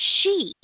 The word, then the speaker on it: sidhe: The leprechauns are very big there, and they call the fairies she, and it's spelled S-I-D-H-E, she.